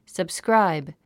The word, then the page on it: subscribe: səbskráib; サブスクラァイブ